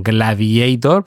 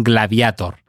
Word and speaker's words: Gladiator: En inglés es lógico que pronuncien Gladiator, porque para ellos la A se pronuncia EI, ¿verdad, Juaniquilator? Pero nosotros somos españoles, la A se pronuncia A, y en latín es que la A se pronunciaba A. El gladius, la espada, hacía que el portador de la misma fuera un gladiator. Y por eso en español debemos decir Gladiator, y en este caso Gladiator II, con sus números romanos, como debe ser.